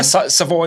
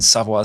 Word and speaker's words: Savoy: No, Savoy is the English pronunciation and Savoy is the French pronunciation.